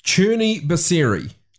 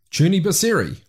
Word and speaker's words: Crni Biseri: named after a film called Crni Biseri. That's my attempt at pronouncing a word spelt C-R-N-I. And then B-I-S-E-R-I.